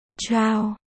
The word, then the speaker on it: Triều: Nguyên văn chữ Triều là chào, chữ này có 2 cách đọc cháo và chào.